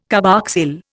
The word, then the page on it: carboxyl: Indian